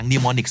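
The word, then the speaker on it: mnemonic: n e m o n i c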